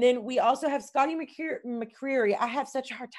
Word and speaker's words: McCreary: You got to say it like there's marbles in your mouth. Like it's like when I say Louisville. – It's when you say Louisville. So it's like McCreary.